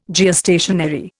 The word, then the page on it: Geostationary: Indian